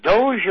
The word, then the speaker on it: Doge: Yep, Constantinople, there was, the Crusades, the Doge of Venice, D-O-G-E, is that how it's better pronounced than dog, I suppose? – Yeah, Doge.